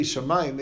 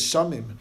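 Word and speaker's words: Shamayim (שמים): Okay, so like, how do you know, like, to pronounce Shamayim, if you look at the letters, it doesn't say Shamayim, it should be read as Shamim, right? Shamayim, you're with a patach, it's like there's an extra letter in there, an aleph, right?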